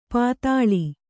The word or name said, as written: ಪಾತಾಳಿ